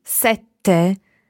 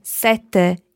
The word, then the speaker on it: sette: It's very similar, but number seven is double T, sete, and sete meaning thirst is single T. So when you say thirst, you can have a very long E, sete, while when you say number seven, sete, you need to be very short on your E to allow a very long T sound, sete.